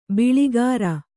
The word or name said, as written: ಬೊಳ್ಳುಗರೆ